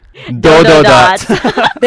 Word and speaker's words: Dododots: How do you pronounce it? – Dododots. – There you go, we got it right.